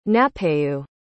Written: napeu

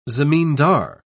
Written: zamindar

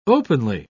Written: openly

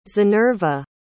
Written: zinerva

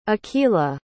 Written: akeelah

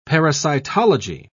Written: parasitology

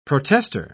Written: protester

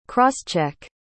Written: cross-check

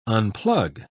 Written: unplug

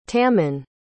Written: tammen

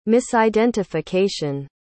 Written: misidentification

